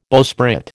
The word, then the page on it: bowsprit: American English